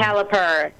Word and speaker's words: Caliper: And the other one you said was CalPERS. Is that correct, or did I say that wrong? – Caliper. Yeah, no, oh, it's Caliper. Like the mechanics, Caliper.